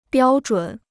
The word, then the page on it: 标准: biāo zhǔn